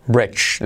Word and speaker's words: bridge: All right, otra palabra que tenemos que saltamos, ah, que no hay que pronunciar la, D realmente. Brech, brech, brech, porque es un sonido J. Bridge, lo que suena ahí, ¿vale?